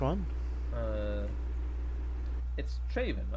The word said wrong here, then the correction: Draven: right? Yeah, I want to make sure I, I got the name right in my head. Uh, Draven.